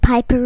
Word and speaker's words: Piper: After he said Piper rhymes with Viper, she went like this.